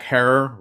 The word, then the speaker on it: Herer: You know, you can go back and watch clips of Jack Herer, Rhymes with Terror, and Woody Harrelson addressing the crowd as huge wafts of smoke came up.